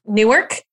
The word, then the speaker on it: Newark: No, it's N-O-O-R-K, Newark. – Newark. That is not how it's spelled. The nor, is that the way that you're supposed to sound? It's a two-syllable word.